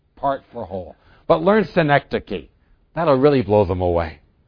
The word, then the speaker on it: synecdoche: It's called synecdoche, and there it's spelled out, S-Y-N-E-C-D-O-C-H-E, synecdoche.